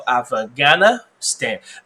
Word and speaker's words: Afghanistan: OK，好，所以重音在那个 G。 OK，好，A F F G H 不发音。 OK，H 不发音，H 不发音，做框感给你看哦。 So Afghanistan，然后为什么要念的呢？